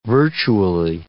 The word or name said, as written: virtually